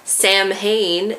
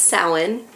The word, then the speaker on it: Samhain: He wrote several books about ancient Celtic traditions, including the festival of Samhain, or as you might know it, Samhain, if you try to pronounce it how it is spelled. It's actually pronounced Samhain.